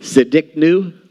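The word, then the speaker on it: Tsidkenu: I know that, so I'm going to say it Siddiqnu. Something like that. All right, the T is silent. I know I could be wrong, but it's, yes.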